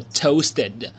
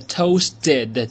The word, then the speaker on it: toasted: 好，第二个词呢，它的意思呢它的这个念法呢叫做，来跟我一起念啊，叫做 toasted，它的意思呢是烤的，烘烤了的，叫做 toasted。注意啊，如果是美式的发音呢，那么这个 toasted 中间这个 t 的发音呢你可以浊化成 d，所以呢念成 toasted，当然你也可以念成 toasted 也是可以的啊，只是一个发音上的习惯的不一样，我是喜欢念成 toasted，它的意思呢是烤的。